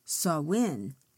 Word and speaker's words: Samhain: And others, and one was called Samhain, spelled S-A-M-S-A-I-N, but pronounced Samhain.